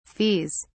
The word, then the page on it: fees: British English